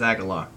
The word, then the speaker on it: Agalloch: And I pronounce it Agalosh. But I believe it now that it is Agalloch.